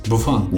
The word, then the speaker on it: Buffon: How do you say it? – Buffon. Gianluigi Buffon. Buffon. Yes, yes, yes.